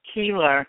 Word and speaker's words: Keillor: Killer. How do you pronounce your last name? – It's Keillor. It's not pronounced the way it's spelled, right? Keillor. – Keillor.